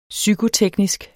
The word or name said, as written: psykoteknisk